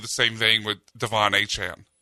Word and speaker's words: Etienne: Like, his name is Etienne. It's been Etienne. Since he was at Clemson, he signs with the Saints and he goes, that's not how you say my name. It's Achan.